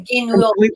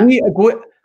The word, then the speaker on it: Guignol: Anais Nin and the Grand Guignol. Did I pronounce that correctly? – No, you said wrong. Guignol. – It worked for me. – Guignol. – Guignol. Thank you.